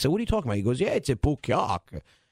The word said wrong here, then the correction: Pequot: And I said, what? I said, I don't think that's how you pronounce it. And can you say it? You know the tower I'm talking about. – It's Pequot.